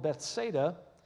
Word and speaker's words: Bethsaida: And now Jesus is in a town called Bethsaida. And you spell it like Beth said, S-A-I-D-A. Beth said a good thing today, Bethsaida.